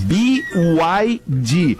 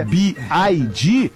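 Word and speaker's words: BYD: Exatamente. B-Y-D. O cara que vende B-Y-D e fala B-I-D, já não compro dele.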